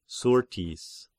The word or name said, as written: Syrtis